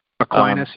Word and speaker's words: Aquinas: I always get the name wrong. Aquinas. – Aquinas, yeah. – Aquinas, thank you.